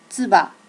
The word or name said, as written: tsuba